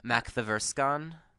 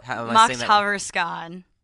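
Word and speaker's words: Makthaverskan: Mac the Verscon. – Oh, Mocked Haverskan. – Oh, I wasn't even close. – Yeah, well, it's spelled like you just break it up. Like, mocked is Swedish for power.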